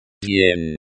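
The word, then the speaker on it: Dien (день): Dien is pronounced with a soft D sound, which is similar to the D in day, but with a slight Y sound after it.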